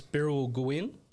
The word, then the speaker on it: Birrawal Gwin: Yeah, it's Birrawal Gwin. So it means strong spirit.